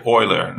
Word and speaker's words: Euler: É, se a gente lê em português, escreve Euler, né? E-U-L-E-R. Mas como, enfim, Leonhard Euler, né? Ele era alemão, então a gente fala, a, tenta fala a pronúncia mais parecida com a língua materna dele, então é Euler.